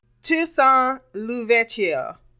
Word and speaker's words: Toussaint L'Ouverture: Oh my God, I cannot say his last name. Ouverture. – A few moments later. – Toussaint L'Ouverture.